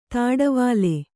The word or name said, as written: ದೋಟಿವಲೆ